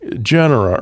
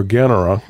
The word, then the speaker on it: genera: What they do is they first begin with a description of three genera, or genera, depending on how you want to pronounce Latin. I was taught G, the church pronunciation.